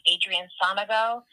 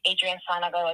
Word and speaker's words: Adrianne Sanogo: So on social media, I am at Adrianne Sanogo.